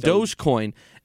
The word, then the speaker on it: Dogecoin: In Dogecoin.